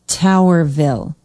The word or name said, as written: towerville